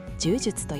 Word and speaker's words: jj: この記事で紹介されている JJ 柔術と読みますは、そんな Git の痛みを解消するために作られた次世代のバージョン管理システムなんです。